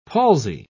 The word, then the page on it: palsy: British English